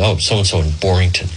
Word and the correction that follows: Barrington: And someone who's a total nitwit comes in and says, I hate to interfere, but it's pronounced Barrington.